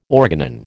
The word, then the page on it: organon: American English